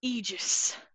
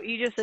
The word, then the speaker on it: Aegis: I'm talking about Aegis. In case you don't know how that's spelled, that's A-E-G-I-S. It's hard to pronounce. – Doesn't I've heard make it sense. Is Aegis. – I have too, but I looked Aegis. It up on the internet and it says Aegis.